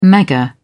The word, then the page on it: mega: British English